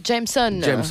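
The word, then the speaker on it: JMSN: Jameson.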